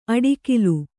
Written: ಅಡಿಕಿಲು

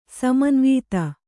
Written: ಸಮನ್ವಿತ

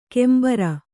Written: ಕೆಂಬಾರ

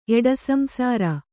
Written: ಎಡಸಂಸಾರ

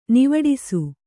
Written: ನಿವಾಡಿಸು

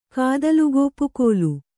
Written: ಕಾದಲುಗೋಪುಕೋಲು

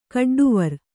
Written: ಕಡ್ಡುವರ್

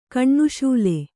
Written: ಕಣ್ಣುಶೂಲೆ

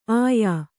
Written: ಆಯಾ

